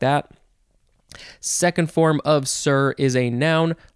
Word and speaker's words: cere: Next we have sir. I think that's just how it's pronounced. It is spelled C-E-R-E, sir, first form.